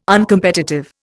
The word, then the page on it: Uncompetitive: Indian